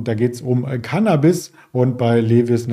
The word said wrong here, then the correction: Levi's: natürlich oder Levi's, wie es ausgesprochen wird, geht es um ja, Bekleidungsmittel und eine Biofrontera.